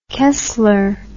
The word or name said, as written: kessler